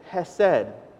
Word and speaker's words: hesed: It's actually out throughout the whole Bible, but in the Hebrew and the Old Testament, it says it's hesed. And actually, if you say it right, you're supposed to almost be like spitting as you say the first syllable, and I'm not going to try to do that right now. But maybe later. But hesed is the nicer way to say it, just with a normal H sound.